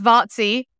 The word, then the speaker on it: Vaci: My surname is Vaci, which I'm sure will be written somewhere. – Yeah. – So it's pronounced Vaci, just because nobody can ever pronounce it.